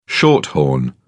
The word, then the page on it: shorthorn: British English